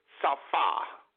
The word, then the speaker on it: tsaphan: They have said, and let me go back to hidden ones, because the Hebrew word there is Safan, Strong's Concordance, H6845. It is spelled T-S-A-P-H-A-N.